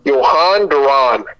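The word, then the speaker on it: Jhoan Duran: So, yeah, I think it's Johan Duran. It rhymes, I think.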